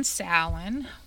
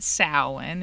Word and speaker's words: Samhain: And yes, we pronounce it Samhain. It's not Samhain. I remember seeing a TV show one time where somebody called it Samhain and I about exploded. I was like, no, no, it may be spelled that way, but that is not how you say it.